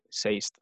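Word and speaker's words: Sazed: ¿Saced? Sí, yo le digo como, sí, como se lee, Saced, aunque Brandon Sanderson le dice Seist o algo así. – Claro, es que en inglés en verdad eh, sería Seist o sí, algo así.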